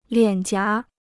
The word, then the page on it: 脸颊: liǎn jiá